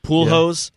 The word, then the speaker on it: Pujols: Pujols was okay. Did I say that right? – Yeah, Pujols. Yeah.